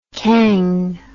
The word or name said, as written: cangue